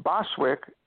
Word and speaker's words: Boswijk: So Boswick, B-O-S-W-I-J-K, the J is silent, meant town in the woods.